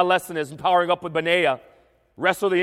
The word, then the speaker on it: Benaiah: And so as we think about mighty men and Benaiah, and that's pronounced correctly, by the way.